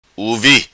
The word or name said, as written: uvi